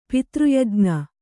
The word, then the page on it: ಪಿತೃಯಜ್ಞ: pitř yajña